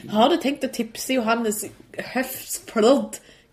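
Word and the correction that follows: Høsflot: Hvordan uttaler han det mellom høstflott? – Johannes Høstflott. Klæbo. Sier bare det.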